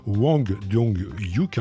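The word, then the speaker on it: Hwang Dong-hyuk: dont j'ai écorché d'ailleurs tout à l'heure le nom du réalisateur, Wang dong yuk hein, c'est son nom.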